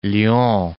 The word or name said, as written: Lyons